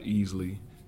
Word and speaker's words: Easley: Yes, Thomas Rashad Easley, E-A-S-L-E-Y.